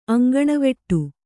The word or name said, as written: ಅಂಗಣವೆಟ್ಟು